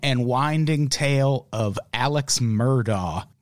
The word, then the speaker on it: Murdaugh: It's, well, locally, it's Murdaugh. That's the way they say it.